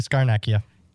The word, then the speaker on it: Scarnecchia: Starnecchia, is that how you say it? – Yep, Dante Scarnecchia.